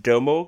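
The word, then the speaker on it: Domhnall: I think his name is pronounced Domo Gleeson. I think I think you just kind of like drop the the H and the N, and you kind of drag out the O.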